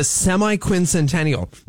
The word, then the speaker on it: semi-quincentennial: The semi-quincentennial. The SQ, baby. We call it the SQ, the semi. Am I saying it right? Quincentennial? – Yeah, or okay.